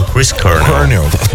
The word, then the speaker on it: Chris Cornell: Peraí, que o menino do Mississippi aqui pronunciou correto. O Chris Cornell.